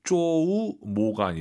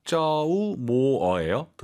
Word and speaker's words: 周末: 주말 발음을 쪼우 모가 아니라 쪼우 모어예요.